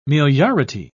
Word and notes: meliority: British English

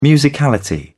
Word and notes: musicality: British English

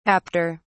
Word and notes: apter: British English

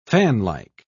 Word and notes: fanlike: British English

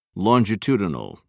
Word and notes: longitudinal: British English

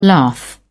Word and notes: lath: British English

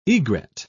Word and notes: egret: British English

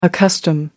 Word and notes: accustom: British English

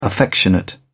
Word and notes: affectionate: British English

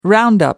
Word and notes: roundup: British English

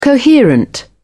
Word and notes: coherent: British English

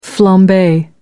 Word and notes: flambe: American English

